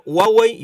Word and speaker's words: Huawei: Lo que pasa es que Huawei es una pronunciación más basada en la cultura china. – Sí. – Es como un fonograma que dice Huawei, casi como si era Juan, con la J de Huawei. – Ajá. – Y Huawei también porque es una pronunciación internacional. – Ah, ok, perfecto. – Las dos son válidas, digamos.